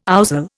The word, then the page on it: ouzel: Indian